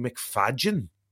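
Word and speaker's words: Macfadyen: I think he actually likes to pronounce his name Matthew McFadgen. – Oh yeah, I guess he because I suppose it's a little bit Welsh, is it?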